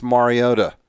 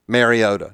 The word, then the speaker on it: Mariota: of how to pronounce Marcus Mariota, because he repeatedly corrected those who did not say it correctly. Mariota. Nope. Mariota. So it's Mar, like Mar-a-Lago.